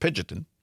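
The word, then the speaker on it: Pingeton: It says Robin Pigeonton, I believe it's pronounced Pigeonton, must be one heck of an interview.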